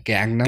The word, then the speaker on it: Gangnam: So it's Gang, N-A-M, Gangnam? – Gangnam.